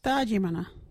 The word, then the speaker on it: Thaajimanha: Our local Wajiri word is Thajimana. What is Thajimana? Thajimana is opening out or swearing. You can use Thajimana in this sentence. Are you that Thajimana? Thajimana is spelt THAAJIMANHA. Can you say Thajimana?